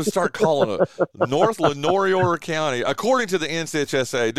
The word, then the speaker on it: Lenoir: Lenoir County? – Lenoir, yeah.